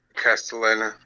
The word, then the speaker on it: Castellaneta: Oh, Dan Castellaneta, I mean, yeah.